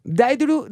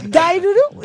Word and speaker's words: Daiduru: Daiduru, that's the pronunciation in Celtic. – In Celtic. – Yes, Gaelic.